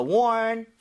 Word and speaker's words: warn: 근데 발음할 때 R이 살아있죠. – 예. – So, one, 이러면 안 돼요. 앞으로 더 이상 warning, 이러면 안 되고요. 혀를 뒤로 당긴 채, ning 하셔야 됩니다.